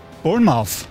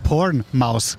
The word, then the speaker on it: Bournemouth: Bournemouth. Oder wie spricht man es richtig aus? – Hilft mir weiter. – Bournemouth. – Bournemouth. – Bournemouth. – Bournemouth. – Bournemouth. – Not porn mouse.